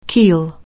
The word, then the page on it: keel: anglais